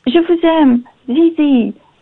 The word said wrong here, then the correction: ZieZie: Zai Zai. Sorry. – Oh, get his name right, mate.